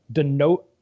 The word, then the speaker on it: donovanosis: It's called the donovanosis.